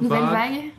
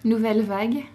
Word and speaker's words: Nouvelle Vague: Nouvelle Vague. – Okay, I've been wondering how to pronounce that. – Nouvelle was good, just the A is an R.